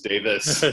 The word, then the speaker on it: Davies: That was the first time I'd ever learned that you pronounced what I read as Davies and it was Davis.